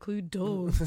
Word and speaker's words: Cluedo: You're stupid, Cluedo. – It's actually Cluedo. Just what? Everybody pronounces it wrong.